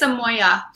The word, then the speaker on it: Amoia: No, you didn't butcher it. It's Amoia. Very, very Amoia. Close.